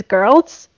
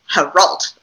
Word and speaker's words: Geralt: It's a soft G. It's Geralt.